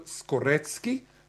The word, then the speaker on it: Skorecki: Karl Skorecki, and he pronounces the C's as tz. It's not like Moroccan, which is Mrocki, or it's not like Slaki, which is Slutski. He is a real Skorecki, and he is an expert of that.